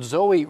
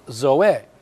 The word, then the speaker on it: Zoe: Now, I know the word Zoe really is properly pronounced Zoe, but we all usually say Zoe in our vernacular.